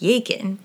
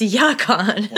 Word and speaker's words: Yacon: It's basically spelled bacon without the B, so it looks like Yakin, but it's Yacon.